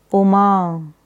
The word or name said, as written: Uma